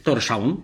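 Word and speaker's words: Tórshavn: w Torszaun, tak się wymawia stolicę mm.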